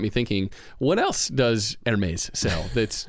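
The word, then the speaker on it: Hermès: And same thing is true with this Hermes envelope.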